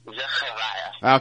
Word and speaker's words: Zechariah: My actual first name in Hebrew is pronounced Zechariah.